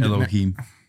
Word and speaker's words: Elohim: Elohim. This gained... – Elohim. – Elohim? Is that what it is? – That's what it's called.